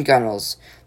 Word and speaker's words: gunwales: The top edges of canoes and rowboats are called the gunwales, pronounced in gunnels.